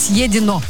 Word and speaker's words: съедено: Все, что съето, должно быть съето. Ну, в смысле, съедобное. Должно быть съедено. Я знаю, как правильно произносить это слово, а то солнечное имя тут закрывало за эфиром.